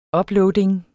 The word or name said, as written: uploading